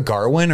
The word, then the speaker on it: Gawain: Did you hear a Garwin, or was that just like a dialect thing? – It's like Old English or Middle English pronunciation.